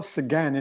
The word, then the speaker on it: Sagan: It was Carl Sagan, is that how you pronounce his name? S-A-G-A-N, the astrophysicist. – I've always said Sagan, but I think he wouldn't be offended.